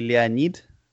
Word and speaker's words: Leonid: Well, in Russian you say Leonid.